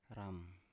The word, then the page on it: ram: Biak